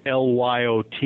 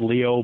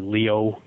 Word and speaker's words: Lyot: And the asteroid impact basin that apparently formed and destroyed Mars' climate system is called LYOT. It's pronounced LEO, but it's French. So we'd pronounce it Lyot, but it's actually LEO, pronounced LEO.